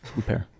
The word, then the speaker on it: Huppert: Uh, I liked uh, uh, uh, how do you pronounce it? Isabelle Huppert. – Huppert. yes, I, I loved once she got to really ramp up the crazy.